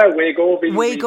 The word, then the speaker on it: Wegovy: Wegovy? – Yeah, Wegovy.